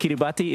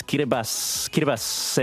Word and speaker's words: Kiribati: We remind people every year on the during this week that it's not, like, it's spelled Kiribati, but it's not pronounced that way. Like, look, the spelling is one thing, but the T-I in the language, across the language, is pronounced as an S sound. So if you see a T-I, it's just an S. So not Kiribati, it's Kiribas.